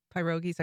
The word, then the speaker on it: pierogi: And your mom helps you make your pierogies. I'm going to say it wrong, not nicely. Like pierogi. – Pierogi. – Yeah, pierogi.